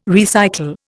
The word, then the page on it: recycle: Indian